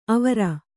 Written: ಆವರು

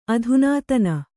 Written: ಅಧುನಾತನ

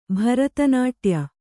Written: ಭರತನಾಟ್ಯ

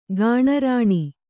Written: ಗಾಣರಾಣಿ

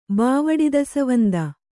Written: ಬಾವಡಿದಸವಂದ

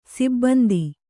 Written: ಸಿಬ್ಬಂದಿ

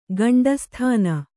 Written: ಗಂಡುಸುತನ

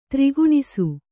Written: ತಾರ್ಕಣಸು

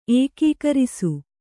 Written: ಏಕೀಕರಿಸು

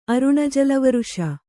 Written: ಅರುಣಜಲವರುಷ

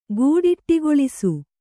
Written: ಗೂಡಿಟ್ಟಿಗೊಳಿಸು

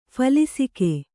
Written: ಫಲಿಸಿಕೆ

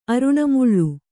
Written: ಅರುಣಮುಳ್ಳು

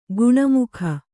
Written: ಗುಣಮುಖ

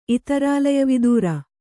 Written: ಇತರಾಲಯವಿದೂರ

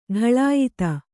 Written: ಢಳಾಯತ

